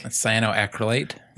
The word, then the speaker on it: cyanoacrylate: Cyanoacrylate, I think. – Cyanoacrylate.